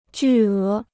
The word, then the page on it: 巨额: jù é